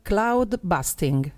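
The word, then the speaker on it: Cloudbusting: Cloud busting, perfetto. – Cloud busting o cloud? – Cloud. – Cloud busting, vai.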